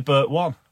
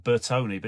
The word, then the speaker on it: Bertone: It's like Bert One. – Yeah, that's right. Yeah. Designing all those cars. – Yeah, the Italian cars. Some people pronounce it Bertone, but it's actually that's wrong.